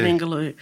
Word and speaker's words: Ningaloo: Most recently, he has written, narrated, and executive produced a nature documentary series called Ningaloo. Is that a correct pronunciation? – Yeah, Ningaloo.